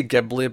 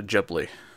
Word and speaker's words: Ghibli: I always say Ghibli, but I believe the correct pronunciation is actually Ghibli.